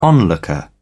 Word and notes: onlooker: British English

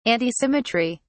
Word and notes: antisymmetry: British English

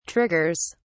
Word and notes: triggers: British English

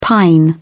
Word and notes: pine: British English

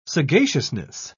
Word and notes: sagaciousness: British English

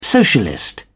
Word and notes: socialist: British English

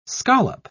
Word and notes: scollop: British English

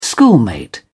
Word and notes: schoolmate: British English

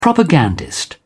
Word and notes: propagandist: British English

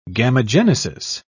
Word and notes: gamogenesis: British English

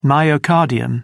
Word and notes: myocardium: British English

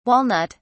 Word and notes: walnut (s): British English

